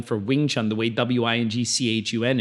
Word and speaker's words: Wing Chun: So the V-I-N-G-T-S-U-N spelling is kind of like also now kind of a bit of a generic spelling for the art, but it should always be pronounced Wing Chun.